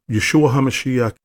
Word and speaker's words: Yeshua HaMashiach: Yeshua HaMashiach is how you pronounce his name in Aramaic, which is the language Jesus Christ and people in that region spoke at that time.